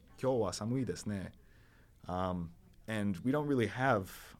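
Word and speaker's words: ne (ね): It's pronounced ne, and it means like, oh, doesn't it?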